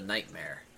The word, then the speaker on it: Knightmare: It's the Knightmare. But Nightmare is spelled with a K at the beginning.